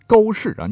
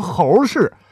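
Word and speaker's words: 缑氏: 还有玄奘故里沟氏啊，你不能读成猴氏，猴子旁边是个反犬旁，咱换成绞丝旁啊。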